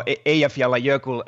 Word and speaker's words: Eyjafjallajökull: going to try to pronounce this now Eyjafjallajökull uh, vol- volcano, and uh, you know which forced a lot of flights, hundreds of thousands of flights to be cancelled.